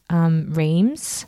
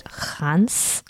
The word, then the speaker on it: Reims: I stayed in a place called Reims. – For a year? – For a year. They pronounce it Hans. I don't know. – Hans. – Mm-hmm. I don't think I'm pronouncing it properly still.